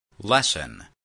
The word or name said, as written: lesson